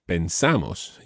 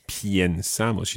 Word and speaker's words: pensamos: Think of pienso, but pensamos. You don't say piensamos. You don't need to say piensamos because the stress is not on that first syllable.